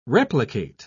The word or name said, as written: replicate